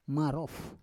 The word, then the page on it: marof: Roon